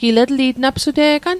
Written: etliatl

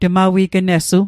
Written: tmawignessu

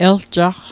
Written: eljaqq

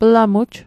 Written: plamuj

